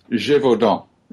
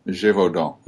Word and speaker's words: Gévaudan: Well, how should it best be pronounced? – Gévaudan. Gévaudan. You wouldn't actually pronounce the N. – The N at the end.